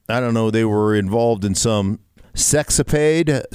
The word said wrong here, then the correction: sexcapade: sex, sex, how do you say that? Sex, sexcapade. Sexcapade.